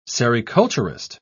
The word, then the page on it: sericulturist: British English